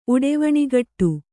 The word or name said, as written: ಉಡೆವಣಿಗಟ್ಟು